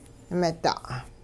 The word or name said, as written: mida'